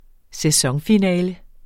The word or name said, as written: sæsonfinale